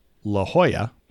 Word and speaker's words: La Jolla: I was wondering, and I know that out here we've had some activities that have made national news, and one of the famous places that they screw up is La Jolla, and that usually gets pronounced as La Jolla to the uneducated, because it's a J-O-L-L-A, which is H, you know, phonetically it's H-O-Y-A. – Yes, a double L always makes the yuh in Mexican, right? – Spanish.